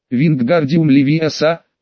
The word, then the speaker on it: Вингардиум Левиоса: «Надо произносить так. Вингардиум Левиоса, в слоге гар должна быть длинная А».